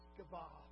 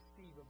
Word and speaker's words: Sceva: How you say his name in Greek. Sceva, Hebrew actually, Sceva. Now, I know you want to say Stephen, but it's not. It's Sceva. You actually pronounce the C there.